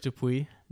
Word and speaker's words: Dupuis: Under my own name, Derek Dupuis, D-U-P-U-I-S is my last name.